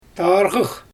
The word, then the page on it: tarɣɯx: Xyzyl